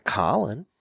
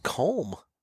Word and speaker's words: Colm: His name's not Colin. – It's not? – It's like Colm.